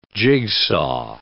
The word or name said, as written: jigsaw